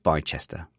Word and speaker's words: Bicester: It's pronounced Bicester, but okay.